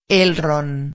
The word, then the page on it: el ron: espanhol